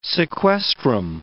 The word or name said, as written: sequestrum